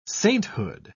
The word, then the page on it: sainthood: British English